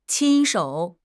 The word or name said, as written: qin1 shou3